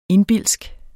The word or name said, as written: indbildsk